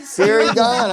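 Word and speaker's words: Ciryl Gane: I'll take Peter Yan and Cereal Gone. – He called him Cereal. He said Siri Gone. I said Siri Gone. – He pronounced the man's name as Cereal.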